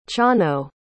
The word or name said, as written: chano